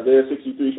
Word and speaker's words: Isaiah: Yeah, Isaiah 63, from one new moon to another.